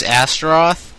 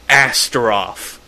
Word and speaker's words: Astaroth: Because it's like the strongest syllable in the word Astaroth.